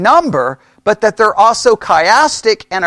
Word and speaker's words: chiastic: Chiastic is spelled C-H-I-A-S-T-I-C.